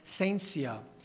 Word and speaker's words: scientia: It's not that science is actually not pronounced science in Latin. It would be pronounced scientia, scientia, and it means knowledge.